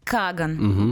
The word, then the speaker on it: Каган: Вообще-то здесь. Каган? Каган. – Угу. Да, я смотрела ударение. Все угу. правильно.